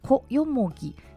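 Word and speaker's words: 小ヨモギ: はい、はい、いわゆる原生林を通るところはブナノキ峠というところへのルートで、このトロッコ道を通るルートはコヨモギ、小さいにカタカナでヨモギと書いてコヨモギへのルートとなります。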